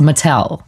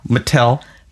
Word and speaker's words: Mattel: Like, you should say it's an American brand, right? So you should say it like an American, correct? – Yes. – It's Mattel. – Mattel.